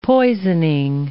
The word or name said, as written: poisoning